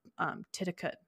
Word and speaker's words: Titicut: It's Titicut. Yeah, it's not Titicut. It's T-I-T-I. T-I-T-I-C-U-T. So it's Titicut Follies.